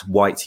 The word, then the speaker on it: Whyte: I'm at Whyte UK,